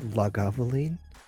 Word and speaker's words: Lagavulin: One bottle of, is it called Lagavulin? – Lagavulin? – Did I say that right? – Lagavulin? I just always say Lagavulin.